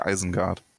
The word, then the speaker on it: Isengard: Sagen die Isengard oder sagen die Eisengard? – Im, hm, äh, im Film sagen sie Eisengard.